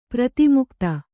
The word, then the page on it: ಪ್ರತಿಮುಕ್ತ: pratimukta; Kannada